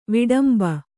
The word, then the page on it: ವಿಡಂಬ: viḍamba